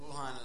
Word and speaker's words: Wuhan: But the A in Chinese, it's like you pronounce like ah. So Wuhan, it's like W-U-H-A-N, it's Wuhan. Shanghai, S-H-A.